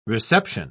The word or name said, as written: reception